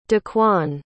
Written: dequan

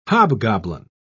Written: hobgoblin

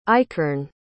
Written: ikerne

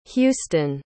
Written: Huston

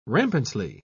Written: rampantly

